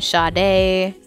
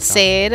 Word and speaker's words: Sade: Did some Sade. We did. – Oh, yeah. – Yeah, I always pronounce it Sade or something. – Sade? – Yeah. – No, it's Sade.